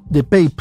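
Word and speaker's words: DePape: El agresor, identificado como David Pipe, de Pape, se debe pronunciar, de 42, 42 años, irrumpió la vivienda al grito de ¿Dónde está Nancy?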